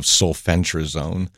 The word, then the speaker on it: sulfentrazone: Sulfentrazone or Dismiss. Just look for the active ingredient of sulfentrazone. And it's like, spell it. – S-U-L-F-E-N. Sulfentrazone. Just sound it out. – Okay, T-R-A zone.